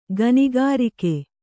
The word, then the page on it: ಗನಿಗಾರಿಕೆ: ganigārike